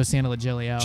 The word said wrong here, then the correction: Santaliquido: Joe Santaliquido.